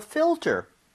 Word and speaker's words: filter: F-I-L. T-E-R. – Mm-hmm. – 자, 근데 발음 주의하셔야 되는 게 지금 fill 할 때 L 있죠? – 네. – 그리고 ter 할 때 R. – 와. – Right? L-R. 이 순서로 되어 있기 때문에 네. – 혀하고 입모양이 제대로 들어가야 돼요.